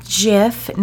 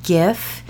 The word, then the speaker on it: GIF: It's one of those things where it's like, well, actually it's pronounced Jif, not Gif.